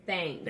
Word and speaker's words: Everythangs: Thangs. – Thangs. – It's not T-I-N-G. It's C-H-A-N-G. Everythangs Corrupt.